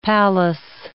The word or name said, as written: Pallas